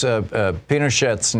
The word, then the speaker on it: Pinochet: You pronounce Pinochet's name with a hard T at the end. I had always heard because people Frenchify his name. It's Pinochet because it's Spanish. It's not French.